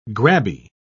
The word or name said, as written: grabby